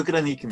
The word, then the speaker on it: Kranik: Algumas pessoas mudam a tônica porque não tem acento, né? Mas é Flávio Kranik mesmo.